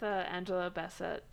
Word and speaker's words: Angela Bassett: I went with Angela Bassett.